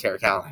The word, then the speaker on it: Caracalla: I thought it would be funny to pronounce it like that, but I'm just going to stick with Caracalla now.